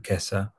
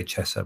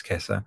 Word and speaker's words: Kessa: If you just Google my dad's name, Matteo, M A T T E O, and then surname Kessa, which is how to pronounce it. A lot of people say Chessa, but it's Kessa and he talked about um, the live fire practices of the army, right?